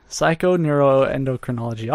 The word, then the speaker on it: Psychoneuroendocrinology: Psychoneuroendocrinology. – Psychoneuroendocrinology. – Yeah. Psychoneuroendocrinology. All one word.